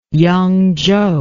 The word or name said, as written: yangzhou